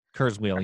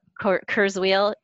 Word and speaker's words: Kurzweil: I probably pronounced his name wrong, but the futurist Ray Kurzweil. – Kurzweil, yep. – Kurzweil, he actually is the founder of Singularity University.